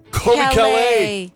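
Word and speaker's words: Caillat: Kobe Calais. – C-A-L-L-A-I-T. Almost spelled like peanut butter parfait. – Yeah, of course. – Kobe Calais.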